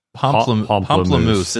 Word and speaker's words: Pamplemousse: Is that, what is it, Pamplemousse? that's the company that makes that, what is it? I know I'm saying it wrong, but it's Pamplemousse, Pamplemois. – Pamplemousse. It's the French word for grapefruit.